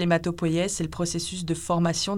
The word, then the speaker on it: hématopoïèse: Alors, dans le laboratoire auquel euh, tu es rattaché, vous vous intéressez à l'hématopoïèse. – Ça va, c'est bien prononcé.